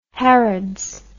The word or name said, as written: harrods